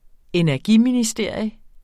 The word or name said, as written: ENERGIMINISTERIUM